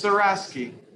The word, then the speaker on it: Surasky: It's Surasky.